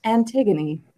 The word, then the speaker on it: Antigone: Luckily, now we have Google, so I can say just like, Google, how do you pronounce Antigone? And she's like, Antigone. Like, oh, okay.